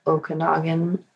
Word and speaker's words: Okanagan: and drove through the Okanagan, I believe that's how you say it.